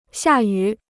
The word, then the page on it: 下雨: xià yǔ